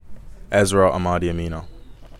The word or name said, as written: Ezra Amadi-Emina